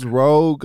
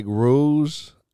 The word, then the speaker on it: rogue: You know, I pronounced rogue like rouge until like ninth grade. – That's kind of the way it's supposed to sound.